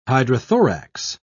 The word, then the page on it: hydrothorax: British English